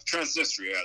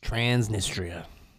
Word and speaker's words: Transnistria: Transnistria, that's how it's pronounced. – Transnistria.